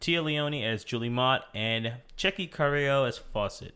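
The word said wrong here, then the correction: Fouchet: I probably butchered that last one, too. – I think it's Fouché. – Fouché? – Yeah.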